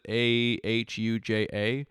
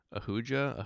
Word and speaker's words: Ahuja: A H U J A. Ahuja.